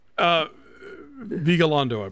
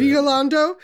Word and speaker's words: Vigalondo: I would love to see, I'm going to need you to correct me on the name, Nacho Vigalondo. – Vigalondo, I believe is how you say Vigalondo.